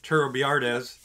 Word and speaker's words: Turrbiartes: No, well, I mean, you gotta roll the R's. Other, I mean, I can say it like a white guy and just be Turrbiartes.